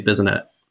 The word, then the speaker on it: Bissonnet: I just say Bissonnette.